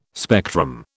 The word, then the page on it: spectrum: British English